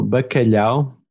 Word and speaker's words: bacalhau: So bacalhau, which is the salted cod, the dry salted cod.